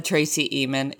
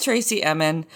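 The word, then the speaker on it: Tracey Emin: It's not, I said Tracy Emin. It's Tracy Emin.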